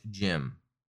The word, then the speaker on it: GM: A.K.A. GM, pronounced Jim.